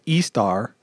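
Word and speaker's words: Ishtar: Uh, in the descent of Estar, uh, and even repeating the same thing. yeah, that really how you say it? – Yeah.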